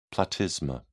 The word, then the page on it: platysma: British English